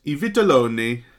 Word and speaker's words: I Vitelloni: And today we're talking about I Vitelloni. – Yes. – In my Italian pronunciation.